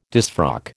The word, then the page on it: disfrock: American English